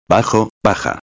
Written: bajo/a

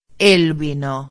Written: el vino

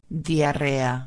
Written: diarrea

